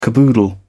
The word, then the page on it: caboodle: British English